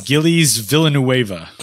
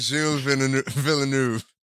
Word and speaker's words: Gilles Villeneuve: I'll go ahead and butcher it. Gillies Villanueva. Not even close. it's the Circuit Gilles Villeneuve.